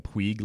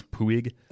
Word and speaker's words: Puig: My disappointment that not only have I been pronouncing Mario's last name incorrectly, I've been calling him Puig like Yasiel, and it is Puig.